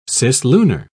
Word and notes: cislunar: British English

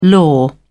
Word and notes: law: British English

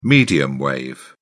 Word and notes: medium wave: British English

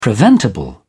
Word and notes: preventable: British English

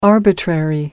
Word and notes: arbitrary: American English

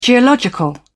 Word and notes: geological: British English

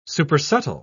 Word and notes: supersubtle: British English

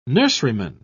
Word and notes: nurseryman: British English

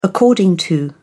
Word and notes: according to: British English

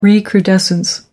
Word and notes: recrudescence: American English

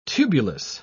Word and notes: tubulous: British English

